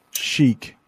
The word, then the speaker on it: chic: And so Mary has a question about British English, and we would pronounce that chic. Chic. Is that how? – Yeah, I would say the same. Chic. So again, I think that's French. I'm pretty sure that's French.